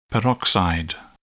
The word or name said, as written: peroxide